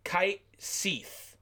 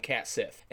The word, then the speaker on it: Kait Sith: Which I only found out recently, the correct pronunciation of his name is Kite Seeth, but it's spelled K-A-I-T... S-I-T-H. So I always call him Cat Sith.